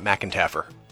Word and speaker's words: McEntarffer: And since many of you are probably in Lincoln, half the family, probably over half the family pronounces it McEntarffer. – Really? – So if you run into a McEntarffer, that's also my family. – Wow. – We're all related. Just some of us pronounce the R and some of us don't.